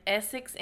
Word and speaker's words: Essex: So this case takes place in Essex, England.